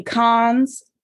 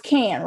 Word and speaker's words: Cannes: So everybody keeps mentioning cons, it's actually pronounced Can, right? Yeah, the Cannes Film Festival. I say cons.